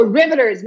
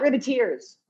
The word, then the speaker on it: Riveters: And it's also Riveters, not Riveteers.